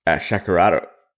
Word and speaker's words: Shakerato: And then recently Pull and Pour made a post about the Shakerato.